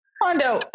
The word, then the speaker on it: Kondo: So Marie Kondo, I said that right, right? – Kondo.